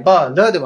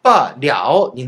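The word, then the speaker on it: 罢了: そして発音もですね、バーラではなくて、バーリャオになるそうです。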